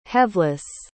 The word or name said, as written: heaveless